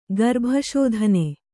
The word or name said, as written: ಗರ್ಭಶೋಧನೆ